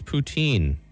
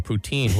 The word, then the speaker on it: poutine: How do you pronounce poutine? Poutine or poutine? Well, I'm from Ontario, so I say poutine.